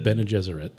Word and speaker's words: Bene Gesserit: Bene Gesserit Titten. – Bene Gesserit.